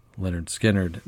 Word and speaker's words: Lynyrd Skynyrd: Pronounced Lynyrd Skynyrd.